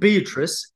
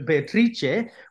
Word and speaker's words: Beatrice: So the designer of this book, Sasha designed at Affirm Press, but the illustrator, Beatrice Cerocchi, Beatrice in English, but Beatrice, which rhymes with Felice in Italian.